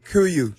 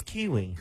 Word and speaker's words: Kuiu: Kuyu. – He doesn't know how to pronounce it. – K-U-I-U.